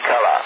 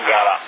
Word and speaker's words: Calà: Comunque c'è, si chiama Calà, non Galà, era quello il problema, della G e della C.